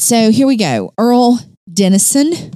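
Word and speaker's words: Earle Dennison: Earl Dennison. – Is that an Earlene? – No, it is not. – Just says Earl. – All right. – E-A-R-L-E, Dennison.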